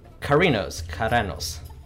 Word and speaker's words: Keranos: Shoot, what's his name? Not Kratos. What? Keranos. – Oh, Keranos. – Yeah. Keranos.